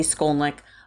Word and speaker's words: Skolnik: At Heidi Skolnick on Twitter and on Instagram.